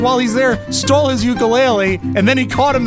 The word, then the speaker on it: ukulele: I love this story because not only is it so Hawaiian, the guy uses the Hawaiian pronunciation of ukulele. Ukulele, or whatever that is they say.